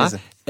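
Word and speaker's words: Meese: wahrscheinlich mhm. anders ausgesprochen wird. Nee, nee, genau so, Jonathan ja, Mese.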